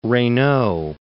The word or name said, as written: Reynaud